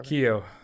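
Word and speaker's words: Keough: What do you say, Ke- Keo or whatever? – Keo is how I pronounce Keo.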